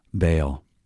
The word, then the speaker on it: Baal: We read about Baal worship, otherwise pronounced Baal.